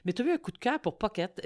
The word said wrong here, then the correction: Phuket: Euh, Pocket, je sais pas comment on le prononce. Phuket, il y en a qui disent euh, Phuket.